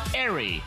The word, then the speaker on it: aerie: Your word of the day from our good friends at Merriam-Webster Dictionary is airy, and that is spelled A-E-R-I-E, airy.